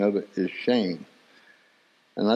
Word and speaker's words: Shem: But the word for name, the original Hebrew word, is pronounced shame. So the word name in Hebrew actually means shame. So when we're born and we get our earthly name, our name actually means shame. I mean, it's spelled Shem.